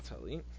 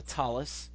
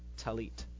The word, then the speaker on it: tallit: In Sephardic pronunciation, it's a T sound, and in Ashkenazi pronunciation, it's an S sound. So talis versus talit.